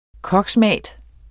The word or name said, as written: koksmat